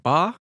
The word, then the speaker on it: Ba: They're based on Egyptian Ba.